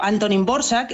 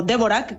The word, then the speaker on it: Dvořák: que es una obra que escribió Antonín Dvořák, escrito Dvořák, que se diferencia un poquito la manera de escribirlo de cómo se pronuncia,